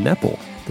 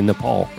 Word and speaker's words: Nepal: Jesse Carey, who joins us regularly from Virginia, is actually in Nepal this week. – Is that how you say it? – Yes. It's how the locals say Nepal.